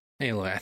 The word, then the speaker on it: Aloy: Is it Aloy or Alloy? – It's Aloy. – Aloy, I thought so.